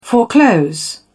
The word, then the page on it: foreclose: British English